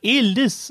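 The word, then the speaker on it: Ehliz: Der Spieler heißt Elitz, geschrieben mit Z, und wird aber Elis ausgesprochen. Wäre es möglich, das richtig auszusprechen?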